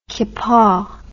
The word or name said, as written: kippa